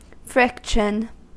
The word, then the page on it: friction: American English